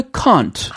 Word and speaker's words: can't: would you and can't 그 can't 발음 있죠. – 음. I can't. – 아 영국식으로.